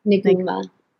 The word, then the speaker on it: Niguma: Is it Niguma? Nijuma? – Niguma. – Niguma.